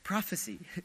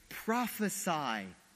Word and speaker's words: prophesy: As they strike him, many of us pronounced it wrong when I was in Washington, in Seattle, celebrating this, and some were saying, prophecy. No, it's a command, it's a verb. They're saying, prophesy as they strike him.